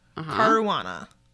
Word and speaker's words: Caruana: Caruana. – I think it is. It rhymes with marijuana.